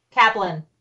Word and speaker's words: Kaplan: Is it Kaplan or Kaplan? – Kaplan.